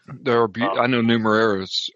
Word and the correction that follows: Numenera: Or did I say it correctly? – No, you didn't. Numenera.